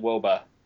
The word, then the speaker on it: wOBA: Uh, but uh, if yeah, uh, the real nerd move would be to pronounce it Woba.